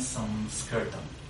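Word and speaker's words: سانسکرتم (संस्कृतम्): سانسکریت میگن سانسکرتم. خب این مه وقتی که پشت سه قرار میگیره خانم سانتی روش اعمال میشه، تبدیل میشه به سانسکرتم. یعنی اینجور تلفظ میشه که انگار مه هست.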